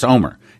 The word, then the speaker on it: Olmert: Now, I want to play an audio here from Ehud Olmert, and he is saying, and I said Olmert, but that's not the correct way to pronounce it. It's Olmert, Ehud Olmert.